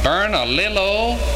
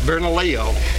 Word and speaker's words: Bernalillo: From Bernalillo, New Mexico. Now you pronounce that for us. – Bernalillo.